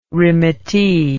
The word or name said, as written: remittee